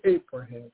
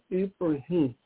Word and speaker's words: Ibrahim: Don't say Abraham, Ibrahim. Don't spell it with an E. No, it's I, Ibrahim.